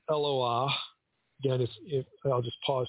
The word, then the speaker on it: Eloah: It's not pronounced Eloha, it's Eloah.